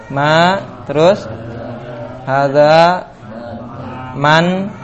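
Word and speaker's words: hadza (هٰذَا): Nah, hadza dibaca panjang, ha-nya ditulis pendek, dza ditulis panjang, dibacanya pendek, hadza.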